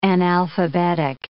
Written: analphabetic

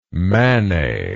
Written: mannai

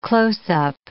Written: close-up

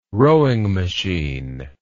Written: rowing machine

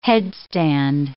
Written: headstand

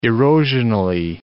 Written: erosionally

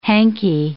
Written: hanky

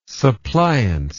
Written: suppliance

